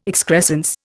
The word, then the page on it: excrescence: Indian